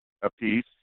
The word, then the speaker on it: Appice: And, Carmine, Appice is probably the way it should be pronounced.